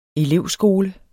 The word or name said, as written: elevskole